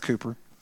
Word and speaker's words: Cowper: It's written Cowper, but it's pronounced Cooper.